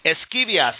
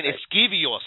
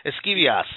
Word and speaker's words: Esquivias: Esquivias, see? Okay. They were saying Esquivios, and I'm like, Esquivios? It's no Esquivios. Esquivias.